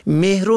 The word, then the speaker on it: مهر: و یا کلمه مهر و مهریه را که حق دختر عروسی شده است مهر و مهریه می خوانند که نادرست است. این کلمه مهر است به فتح میم، نه به کسر میم تلفظ کنیم.